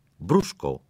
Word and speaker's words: brusco: Brusco se pronuncia brusco, no brusco.